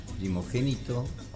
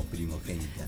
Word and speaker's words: primogénita: Se dice como pronunciaste primero. – Ah, bueno. – ¿Y es primogénita sí. o primagénita? – Primogénito o primogénita.